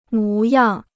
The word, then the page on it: 模样: mú yàng